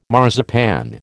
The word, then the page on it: marzipan: American English